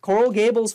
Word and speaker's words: Coral Gables: Coral Gables, Florida, right there.